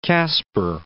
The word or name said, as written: Casper